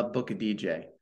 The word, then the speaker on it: Aboukhadijeh: So yeah, just touching on your your first point, uh, the way you pronounce my last name is just think like you're booking a DJ for a party. So it's pronounced uh book a DJ, and uh, there's, there's quite a bit of silent letters in there.